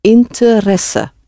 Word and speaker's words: interesse: And it's interesse. Interesse is spelled INTERESSE. So it ends in an E, and you always have to pronounce the ES at the end of a word.